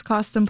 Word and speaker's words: Costin: There are a couple of things Costin Plummer leads with when he meets people these days. One, that his name rhymes with Boston.